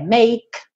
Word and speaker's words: make: Make, não pronuncia a letra E. Para no k, k, Som do K, make.